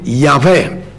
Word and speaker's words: Yahweh: noi lo vocalizziamo in Yahweh, per poterlo pronunciare,